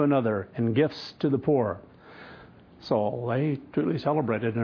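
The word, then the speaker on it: poor (פור): So again, that casting, it was called P-U-R, pronounced poor.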